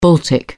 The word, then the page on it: baltic: British English